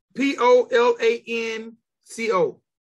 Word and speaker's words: Polanco: George Palenco. – How you spell it? – Polan. C-O.